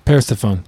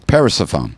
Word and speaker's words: Persephone: how would you pronounce her name? Persephone? – Persephone. – Persephone.